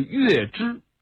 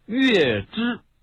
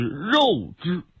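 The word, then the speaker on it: 月氏: 曾经有一度在中小学历史课本上把它标注的读音是肉汁，牛肉羊肉肉汁，但据考证这个注音是不对的。这个月字在过去就读肉，这是以讹传讹，是一种误解。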